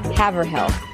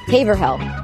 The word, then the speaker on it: Haverhill: Haverhill. Haverhill. Haverhill. – Haverhill? – Haverhill? – Haverhill. Haverhill. Haverhill. – Haver. – Haverhill? – So the H is silent? – Haverhill?